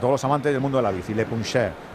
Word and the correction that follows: Le Puncheur: ¿Cómo se escribe Le Puncheur. – Le Puncheur. – Eso es.